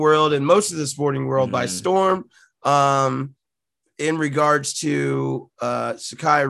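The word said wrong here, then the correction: Sha'Carri: Richardson, and forgive me if I'm not pronouncing her name correctly. Shakari Richardson.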